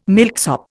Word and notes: milksop: Indian

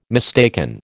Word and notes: mistaken: American English

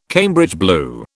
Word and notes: Cambridge blue: British English

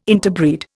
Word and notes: interbreed: Indian

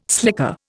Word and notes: slicker: Indian

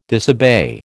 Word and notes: disobey: American English

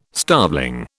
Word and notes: starveling: British English

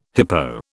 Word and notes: hippo: British English

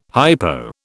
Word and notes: hypo: British English

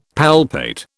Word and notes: palpate: British English

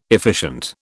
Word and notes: efficient: British English

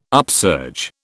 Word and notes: upsurge: British English

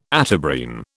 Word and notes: Atabrine: British English

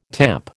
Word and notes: tamp: American English

